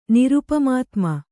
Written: ನಿರುಪಮಾತ್ಮ

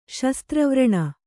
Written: ಶಸ್ತ್ರವ್ರಣ

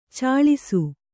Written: ಛಳಿಸು

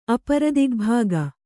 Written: ಅಪರದಿಗ್ಭಾಗ

